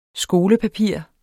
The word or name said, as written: skolepapir